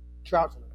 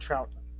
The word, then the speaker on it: Troughton: Troughton. Patrick Troughton.